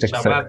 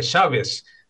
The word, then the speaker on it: Shabes: Son las mismas consonantes, a veces ni siquiera eso, porque a veces la letra Taf, cuando está al final, se pronuncia como S, ¿no? En vez de decir Shabbat, Exacto. – se dice Shabes, se cambia el acento, donde cae todo.